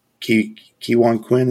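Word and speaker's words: Ke Huy Quan: So is Kiwon Quinn, is how you say his name. I don't want to say it wrong.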